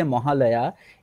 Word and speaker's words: Mahalaya: It is pronounced differently in different languages in India. In Bangla, we say Mahalaya. In Sanskrit, it is Mahalaya. – No, but do you say happy Mahalaya? Is that something people say? Like, happy Mahalaya? – Yeah, your pronunciation is closer to the Bengali pronunciation than the Sanskrit pronunciation.